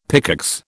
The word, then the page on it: pickaxe: British English